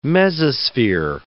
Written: mesosphere

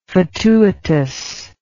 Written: fatuitousness

